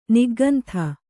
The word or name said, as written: ನಿಗ್ಗಂಥ